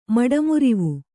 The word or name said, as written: ಮಡಮುರಿವು